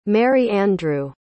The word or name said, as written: merry-andrew